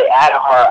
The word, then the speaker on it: Aadhaar: Maybe I'm pronouncing it wrong, the Adhaar, A-A-D-H-A-A-R program? – Oh, the Adhaar.